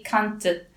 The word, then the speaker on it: Cante: I'm pretty sure that I was calling it Canto Alentejano, but it's actually Cante. So with an E on the end instead of an O on the end.